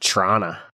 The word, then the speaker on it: Toronto: Toronto? – I think it's pronounced Trana.